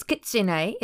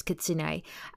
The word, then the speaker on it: Kitsune: Yeah, I just realised I'm pronouncing it wrong. It's Kitsune. It's not how I was saying it before, so it's Kitsune.